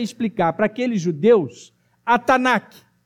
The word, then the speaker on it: Tanakh: Da pronúncia, Tanakh, é o T, u N u K.